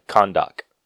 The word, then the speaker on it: Khandaq: Isn't it Khandaq? Is that how I ever say it in the movie? – Hold on, I need to look at how it's spelled. Yeah, Khandaq.